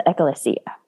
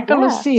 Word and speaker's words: ekklesia: It's the ekklesia. – Yep. Good. I like that. – Mm-hmm. And that is the end of my ancient Greek. – You did a great job pronouncing that word.